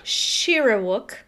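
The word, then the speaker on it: Shyriiwook: He's able to speak, pronounce that word. – Shirawook. – Shirawook? – Shirawook. – Is how you... Shirawook. Is the language of the Wookiees.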